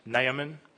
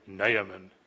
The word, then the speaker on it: Naaman: For the record, I believe it is pronounced Naaman. I checked Bible dictionaries and things. You know, they've got the pronunciations. You can press play. Naaman.